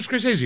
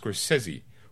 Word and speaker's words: Scorsese: Back in the 80s, it was Scorsese. Now it's Scorsese. When did that change? All right. – It's Igor. – It's whatever. It's pronounced Igor. Excuse me, Mr. Scorsese.